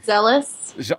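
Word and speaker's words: Szeles: I could never pronounce the name. – Zealous. – Oh, that's how it's spelled? That's how it's pronounced? – Yeah.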